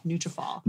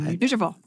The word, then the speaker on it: Nutrafol: How do you pronounce it? Nutrafol. – Nutrafol. – If you say it fast enough, you can't really tell the difference. – Yeah. – Nutrafol. – Fol is from follicle, right.